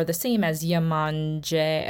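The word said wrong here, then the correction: Yemanjá: Am I mispronouncing? – Oh, Imanja. So mm-hmm.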